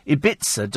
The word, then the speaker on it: Ibiza: Oh, apparently, somebody says, Ibiza does not rhyme with pizza. No, really? Gosh. Thank you for that. I thought the thing was, yes, we're going to Ibiza. Oh, what? How do you pronounce it then? It rhymes with ether.